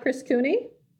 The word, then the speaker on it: Cooney: So with us today, we have Chris Cooney.